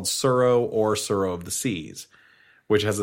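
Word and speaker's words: Tsuro: It's the one with the dragon on the front, and it's T-S-U-R-O. – Yeah. Pronounced like Dow. T-A-O. – Yeah. – But the... it's similar to Surro, and you're moving these pieces, and you just collect points.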